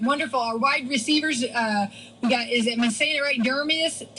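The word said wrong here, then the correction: Demaryius: Dermarius, okay, from the Denver Broncos.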